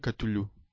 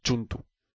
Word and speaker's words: Cthulhu: Lo que pasa es que en Chaosium no hay nivel, pero vamos, si tú vas a la fuente, está claro que la pronunciación correcta debería ser Chuntu.